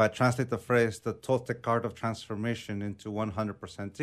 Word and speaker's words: Toltec: I mean, and for those who don't know, what exactly is then Toltec wisdom? And I'm pronouncing it the right way? – Yes, you're correct. Yeah, okay.